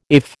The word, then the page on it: IF: American English